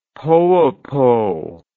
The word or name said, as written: Poopo